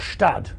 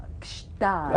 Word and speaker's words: Gstaad: Is it pronounced Gstaad or Stad? – No. Gstaad. – Right, you see. – Gstaad. Yeah, yeah. Do it. – You can't just say Stad.